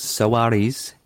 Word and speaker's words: Suarez: There's 3 syllables, Suarez. That's how it is in the intro song.